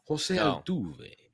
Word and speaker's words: Altuve: I feel like you could have done a little bit better with the Jose Altuve pronunciation. – Jose Altuve. – There you go.